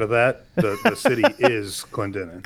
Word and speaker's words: Clendenin: Five men in the nearby town of, how do you say that, Clendenin? Is that right? Clendenin? – That's Clendenin.